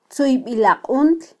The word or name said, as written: tzuybilaquntl